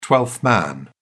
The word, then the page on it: twelfth man: British English